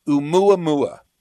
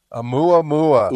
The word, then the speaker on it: Oumuamua: So it has a Hawaiian name, Oumuamua. – Oumuamua. – Oumuamua.